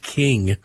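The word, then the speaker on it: Kueng: The other fellow that you referenced, by the way, I think his last name is pronounced King. – King, although it's spelled K-E-U-N. – Right. It's King. It's King.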